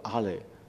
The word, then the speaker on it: alle: all 这个字在德文里面这个字好像读作 alle， A-L-L-E，你们有人懂法语对不对？